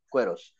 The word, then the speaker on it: Queiroz: Queiroz.